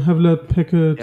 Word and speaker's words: Hewlett Packard: Da haben wir SAP Juni 24, dann Bosch, Schwarzgruppe, HPE, also hier Hewlett, wie spricht man das aus? – Das ist wieder Hewlett Packard Enterprises.